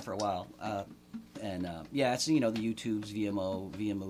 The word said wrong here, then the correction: Vimeo: or I don't know how you say it, but anyway. – Oh, Vimeo. – Vimeo, that's right. Yeah. – Yeah, I guess like video with an M instead of the D, yeah, or whatever.